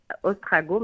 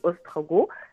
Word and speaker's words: Ostrogoth: Alors, le, premier ministre ne disait pas n'importe quoi parce que ce mot existe effectivement, mais en fait, il avait dit ostrago, mais c'est Ostrogoth » s'écrit O-S-T-R-O-G-O-T-H.